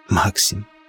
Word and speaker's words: Максим: «Максим», ударение на «А», Максим. Ударение на «а».